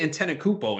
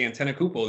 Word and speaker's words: Antetokounmpo: I couldn't say Antetokounmpo until this year.